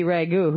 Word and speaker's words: Wagyu: I would raise how do you pronounce it? Wagyu? – I think it's Wagyu.